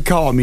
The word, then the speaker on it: calm: With yeah, pronounce the L. Calm.